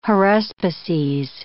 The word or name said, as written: haruspices